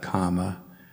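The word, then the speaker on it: kamma: In Pali, those words are pronounced nibbana, kama, and dhamma, and there's a lot of other examples of that.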